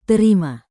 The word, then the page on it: terima: indonesian